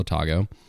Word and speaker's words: Otago: is in Central Otago. And how do you spell that? Otago? – Otago, O-T-A-G-O. – O-T-A-G-O.